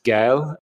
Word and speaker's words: Gael: it says Gay Alan instead of Gael.